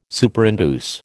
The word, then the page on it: Superinduce: American English